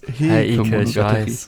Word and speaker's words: Heike Monogatari: Es ist Heike Monogatari.